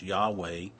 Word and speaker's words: Yahweh: A second name that we find in Genesis is the word YHWH, or sometimes pronounced Yahweh, or sometimes pronounced Jehovah.